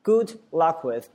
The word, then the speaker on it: good luck with: 祝你什么什么好运，叫 good luck with, good luck with, 那个 D 不发音啊，说听起来像 good luck with, good luck with, 祝你某方面好运，对吧？